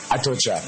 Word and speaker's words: Atocha: Atocha.